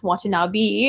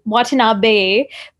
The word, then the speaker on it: Watanabe: His name is not pronounced Watanabe. It is Watanabe,